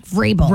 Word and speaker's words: Vrabel: I could never pronounce his last name. – Mike Vrabel. – Vrabel. I just can't do it.